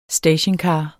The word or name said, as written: stationcar